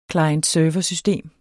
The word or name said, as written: klient